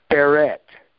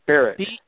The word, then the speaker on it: Barrett: Is it Barry or Barrick? – It's Barrett. Barrett. – Spell that. – Barrett. – B-A-R-R-E-T-T. – Barrett. Okay.